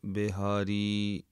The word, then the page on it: bihaaree: Punjabi